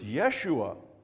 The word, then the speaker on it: Yeshua: It depends on which country you're in, right? In the Hebrew, it's Yeshua.